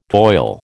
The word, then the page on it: toil: American English